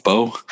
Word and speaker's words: Bo: How do you say his name? Is it Bo, right? Bo.